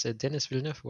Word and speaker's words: Denis Villeneuve: It's Denis Villeneuve.